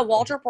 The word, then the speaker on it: Waldrup: Yeah, Waldrup Worldwide family is my platform.